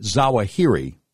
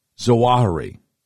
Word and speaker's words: Zawahiri: Now, a lot of times Americans pronounce it Zawahiri, but Arabic folks always pronounce it Zawahiri.